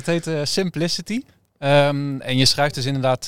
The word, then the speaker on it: Simpli-CT: Keep it simple inderdaad. Dus vandaar Simpli-CT, ja. – Simpli-CT, nou weten we hoe we dat moeten uitspreken, heel goed.